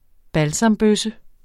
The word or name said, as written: balsambøsse